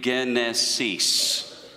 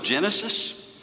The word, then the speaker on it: Genesis: We pronounce the initial, the gamma, which we would transliterate as a G, with a soft G. In Greek, it was a hard G. So we would pronounce it not Genesis, but Genesis. Genesis.